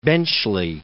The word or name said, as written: Benchley